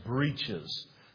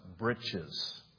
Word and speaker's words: breeches: The word, we would look at it and pronounce it phonetically, breeches. But it's really pronounced britches. That's really how it's pronounced.